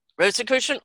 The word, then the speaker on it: Rosicrucian: Rosicrucian Order for more than 40 years, and his studies include understanding how life works on a universal level and intuitive medicine.